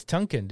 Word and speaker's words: Tonkin: So when I first started hearing about the Gulf of Tonka? Tonka. – I think it's Tonka. Have I been saying it wrong the whole time? – I think it's Tonkin, dude.